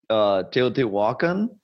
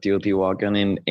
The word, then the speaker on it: Teotihuacan: Actually, there's one about Teotihuacan. That my sister directed that one, and they shot it in the pyramids of Teotihuacan, and that's how they say it. You actually say Teotihuacan, but that they say it Teotihuacan when they're in that episode.